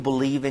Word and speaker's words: believe: 자, believe, 믿다라는 일반 동사 발음할 때 앞에는 be이니까 입술이 붙어야 되고요. be, 두 번째는 입술이 안 붙어야 되죠. v니까 the. So, be. Believe 이렇게 돼요.